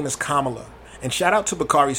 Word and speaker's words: Kamala: Kama, kama, kama, kama, kama, kamala.